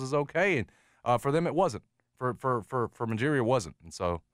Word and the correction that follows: Ujiri: excuse me, I always mispronounce his name. – Yeah, Masai Ujiri.